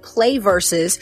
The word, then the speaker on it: PlayVS: Well, Delane Parnell is the founder and CEO of Play Versus uh, spelled P L A Y V S, but pronounced Play Versus, the venture backed startup building the infrastructure for high school esports.